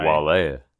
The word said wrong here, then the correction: Wally: Well, I believe it's just pronounced Wally.